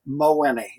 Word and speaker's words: Mawhinney: And that's how he pronounces his last name, or Mowinney, right? – Mowinney, yes.